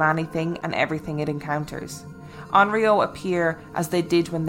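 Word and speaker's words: Onryō: A-O-N-R-Y-O. – Onryo. – We'll say Onryo. – So I had a football player that I coached called Rio. And his name... That's how you pronounce it. And his name is spelled R-Y-O. – Okay. Onryo then.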